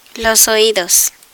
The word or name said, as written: oidos